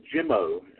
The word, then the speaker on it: Jimmo: Ryan Jimmo. It's his J-I-M-M-O. That's how I can pronounce his name.